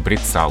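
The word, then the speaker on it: бряцал: Поэт по лире вдохновенной, рукой рассеянной брицал. – Угу. – Да, и это двустишие можно использовать для того, чтобы запомнить, как правильно ставить ударение в этом слове, ну и больше уже не ошибаться.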